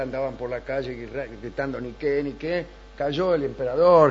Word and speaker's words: Nike: Niqué quiere decir victoria, y no se dice Nike, se dice Niqué, y por eso se llaman así las zapatillas.